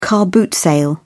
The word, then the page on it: car boot sale: British English